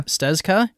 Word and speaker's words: Stezka: Stezka is the Z. I feel like the Z should be silent, but I don't think it is.